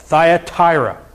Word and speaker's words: Thyatira: That's, if you didn't know how to pronounce that, Thyatira.